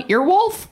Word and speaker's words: Earwolf: On Earwolf? On Earwolf is how that's pronounced.